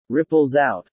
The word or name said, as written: ripples out